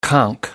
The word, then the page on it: conk out: American English